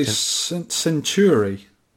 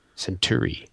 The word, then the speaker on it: Centuri: How do you say that? Centuri, Century? – The Americans say Century. – Century. – Because we had, in the UK, a manufacturer called Century, spelled with a Y. Mm. This is an I on the end, so Century? Let's call it Century.